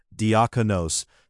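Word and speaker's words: diakonos (διάκονος): In his commentary on Romans 16:1, Origen writes that Phoebe's title, diakonos, pronounced diakonos, shows that women also are instituted deacons in the church.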